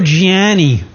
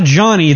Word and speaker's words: Gianni: Gianni. It's not Johnny, it's Gianni.